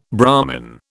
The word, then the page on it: Brahman: British English